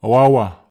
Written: awawa

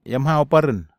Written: iamhau paren

